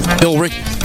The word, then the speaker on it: Billerica: Bill Eric. It's Billerica? Bill? What? How do you pronounce that? Billerica. There's an E in there. – Billerica. – Billerica, maybe? – Billerica. – Oh, that makes sense. Just leave out the E.